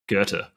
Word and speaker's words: Goethe: He was awarded the Goethe Prize. Is that how you pronounce it? – Goethe.